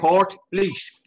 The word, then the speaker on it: Portlaoise: Port Leash.